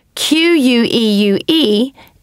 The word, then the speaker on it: queue: Q U E U E is pronounced queue.